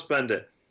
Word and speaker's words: Beindit: Uh, Thomas Beindit. Bend it. Okay. Bend it like Beckham.